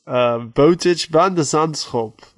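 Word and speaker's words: Botic van de Zandschulp: Bojic van de Zandschulp defeated Carlos Alcaraz at the US Open 6-1, 7-5, 6-4. – Wow. That pronunciation was really on point.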